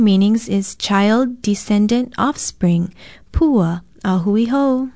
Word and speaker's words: pua: Our Hawaiian word of the day is pua, a very well-known and often used Hawaiian word. It is spelled P-U-A and in most common usage means flower, blossom, even the tassel and stem of sugarcane.